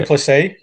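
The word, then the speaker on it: Du Plessis: But Yeah, so looking at the main card they got listed at the moment, they've got Brunson versus De Palacios, or whatever they're pronouncing it. – Duplessis, yep. – Duplessis, sorry, yep.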